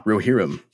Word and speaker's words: Rohirrim: You just answered one of my questions, how to pronounce the word Rohirrim. Give me one more time. Come on. – Rohirrim. – There we go.